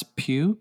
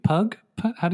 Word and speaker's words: Pugh: So, um, but speaking of classic Madonna, um, there were rumors that Florence Pugh, Pugh, Pug, how do you say her name? Pugh. – Yeah. Pugh. Yeah.